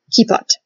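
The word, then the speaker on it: kippot: And also, a big thank you to Sarah, who I actually happen to know in person, uh, who corrected my pronunciation. I hope I'm pronouncing it better this time. Kipa and kipot for the plural.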